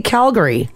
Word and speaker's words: Calgary: Yeah, some people hit hard on the Gary. – This is Calgary. – A lot of people do, surprisingly. – It's spelled. – Yeah. – If you don't know, you don't know. – I think because they say Calgarians, so people hit the G really hard.